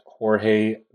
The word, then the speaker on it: Jorge: I personally am on Twitter at J-I V as in Victor, E-G U-I-L-L-A. Um, I'm also on Facebook at Jorge Veguilla, which is spelled J-O-R-G-E. My last name is V as in Victor, E-G-U-I-L-L-A.